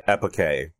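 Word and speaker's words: epoché: So let's talk about the bracketing, the epike. Is that how it's pronounced? That's how it's pronounced in the ancient Greek.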